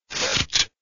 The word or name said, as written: phfft